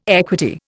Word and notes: equity: Indian

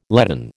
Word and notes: leaden: American English

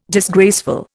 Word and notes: disgraceful: Indian

